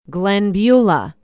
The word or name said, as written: glenbeulah